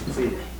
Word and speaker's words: Tsile: Just M-O-L-E-F-E. Molefe. And the last name is Tsile. Tsile. – Thank you. – Two syllables.